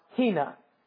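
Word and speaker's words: hina (ἵνα): In Greek, it's pronounced hina, all right?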